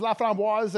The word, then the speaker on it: Laframboise: I'll probably just get my way and trip all over it no, so my last name is Laframboise,